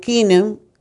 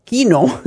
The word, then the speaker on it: Keno: Y en Las Vegas, pues en Radio Kino, Kino Radio Kino, se, se escribe Keno, K-E-N-O y pues hasta las 11,